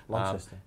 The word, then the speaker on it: Launceston: How do they say it? Lonnie and Lon? – Hobart and Launceston, yeah. Lonnie. – I always say it wrong. – Launceston.